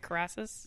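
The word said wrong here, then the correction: Karras: No, Carass. – Carass.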